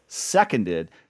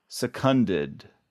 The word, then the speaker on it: seconded: So anyway, this is the beginning of my campaign for us to pronounce this seconded, not secunded, which...